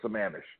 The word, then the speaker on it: Sammamish: And Lake Sammamish. The first M is kind of almost silent.